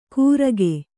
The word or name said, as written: ಗುರಕೆ